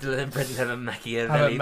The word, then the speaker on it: Machiavelli: I read the Machiavelli book, so I think it's official. – There you go. – That's all you gotta do. – Machiavelli, guys.